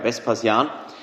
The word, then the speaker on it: Vespasian: Wenn du jetzt ein römischer Stadtführer wärst, hättest du als erstes natürlich gesagt, Kaiser Vespasian, wenn ich es glaube ich richtig ausspreche. Ja, Vespasian.